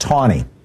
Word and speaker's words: Taney: Except, by the way, his name was pronounced Tawny. There was no W, but that's how they pronounced it.